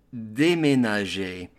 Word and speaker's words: déménager: Pronunciation, déménager.